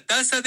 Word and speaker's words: taza: Una taza de café. – Una taza de café. – Taza. Taza. Du måste läspa.